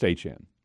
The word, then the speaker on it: Etienne: Yeah, it's supposed to be Achan. – His last name is Achan. – It's pronounced Achan.